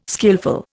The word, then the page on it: skillful: Indian